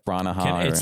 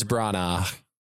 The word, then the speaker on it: Branagh: And then I just really enjoyed Kenneth Branagh. – It's Branagh.